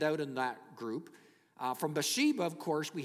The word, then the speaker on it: Bathsheba: And you see a couple of wives here, Bathsheba. Proper pronunciation, actually, Bathsheba.